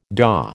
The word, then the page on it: daw: American English